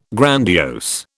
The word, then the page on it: grandiose: British English